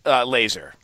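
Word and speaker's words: Lazor: OC Bill Lazar Is that how you pronounce his name? – Laser. – Laser. – Yeah, Laser, yeah. – Boy, he didn't seem laser focused. Wow. I tell you, I don't think that's how you pronounce it. I think that's how he wants us to pronounce it.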